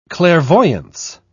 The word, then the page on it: clairvoyance: British English